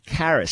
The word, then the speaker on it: charis: And I'm going to leave the Greek word in charis. It's spelled C-H-A-R-I-S, as in charisma or charismatic.